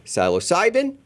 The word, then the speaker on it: psilocybin: Sean has proposed lots of reform or lots of strategies to do nothing more than just simply move psilocybin from a Schedule 1 down to a Schedule 3.